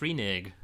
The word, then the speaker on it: renege: It can be pronounced renege. It's an acceptable pronunciation.